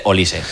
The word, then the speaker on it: Olise: Él ya, pues bueno, ha resuelto todas las dudas con esos cartelones que salen con su nombre y pronunciando para el narradores y demás. Información útil, vale. Dice Olise, vale. Normal.